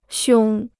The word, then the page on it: 胸: xiōng